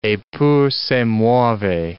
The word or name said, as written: eppur si muove